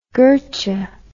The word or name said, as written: gertcha